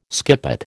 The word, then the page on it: skippet: American English